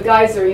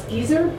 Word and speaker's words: geyser: So the first one is called Geyser, G-E-Y-S-E-R, and there's a play on the pronunciation of that word, because here I've heard people call the geyser, you know, those things in Yosemite, I've heard them pronounce geezer, right? Well, we New Jerseyites say geyser.